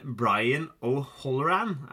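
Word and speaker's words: Brian O'Halloran: Men Brian O'Holloran, er det riktig tru på Dante Hicks? Ja. Veldig bra.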